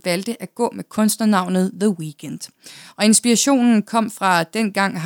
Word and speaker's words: The Weeknd: Men der var jo et kanadisk band, der kaldte sig The Weeknd, så det kunne han jo ikke også hedde. Så han fjernede derfor edet i The Weekends, selvom man udtaler det på samme måde.